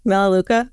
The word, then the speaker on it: Melaleuca: Melaleuca. – Uh-huh. Melaleuca.